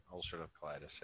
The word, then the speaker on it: ulcerative colitis: And it's funny, you mentioned, and I always mispronounce this incorrectly, ulcerative colitis. Is that how you pronounce it? – Yep. Ulcerative colitis.